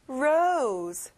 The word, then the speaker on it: rose: 장미, rose. 친구들, 로즈가 아니라 O 발음이 나요.